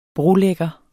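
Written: brolægger

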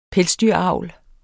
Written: pelsdyr

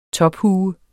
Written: TOPHUES

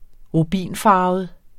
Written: rubinfarvet

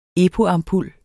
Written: epo-ampul

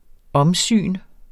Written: omsyn

